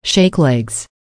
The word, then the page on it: shake legs: American English